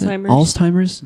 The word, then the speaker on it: Alzheimer's: Is that how you pronounce Alzheimer's. – It? Alzheimer's.